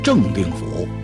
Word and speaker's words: 正定府: 赵云姓赵，名云，字子龙，家住常山正定府。当然有的说是镇定府，有的说是正定府，这个字写出来呢是真。为什么要读成镇或者正呢？